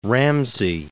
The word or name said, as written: Ramsay